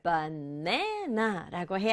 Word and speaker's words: banana: 그런데 이건 강조를 banana라고 해야 돼요.